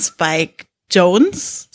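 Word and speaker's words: Spike Jonze: Und wir haben uns ausgesucht, Her von Spike Jones, so wird er wohl ausgesprochen, haben wir gerade recherchiert, äh, der bei euch auf Platz 21 liegt mit im Schnitt 86,3 Punkten.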